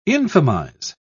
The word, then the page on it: infamize: British English